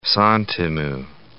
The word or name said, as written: santimu